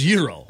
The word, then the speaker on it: gyro: I know you know it's euro. Why do you keep saying gyro? – Stop saying that.